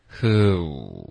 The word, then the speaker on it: who: 这个开头有一个 who，然后大家也要注意，因为这就是我们今天主要在练的发音点，就是 who 这个 oo 的这个长元音。